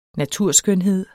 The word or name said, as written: naturskønhed